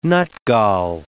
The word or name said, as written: nutgall